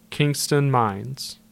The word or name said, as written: Kingston Mines